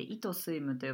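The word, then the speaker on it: eit swim: はいまずあの私たちのブランドのことをちょっとだけお話ししたいなと思うんですけれども、えー、 EIT スイムと書いて「糸スイム」と読みます。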